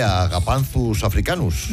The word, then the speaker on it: Agapanthus africanus: Porque es mucho mejor llamar Josefina o Pepita una planta que Agapanthus africanus.